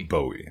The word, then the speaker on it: Bowie: So do you pronounce that last name? Do you say Bow or do you say Bowie? – Bowie. Okay. – Bowie. David Bowie.